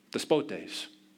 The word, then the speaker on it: despotes: In fact, it's the word despotes. Okay, just take the last syllable off, and that's how we got our English word.